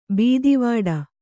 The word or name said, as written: ಬೆದೆವಡೆ